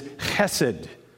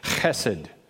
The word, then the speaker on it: chesed: I practiced long and hard to get my ch right, but it is chesed. In fact, if I had a computer, a little thing that I could click on, and it would make, you know, give the way to pronounce it, and that's what it was, chesed.